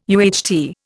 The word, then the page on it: UHT: Indian